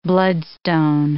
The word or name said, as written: bloodstone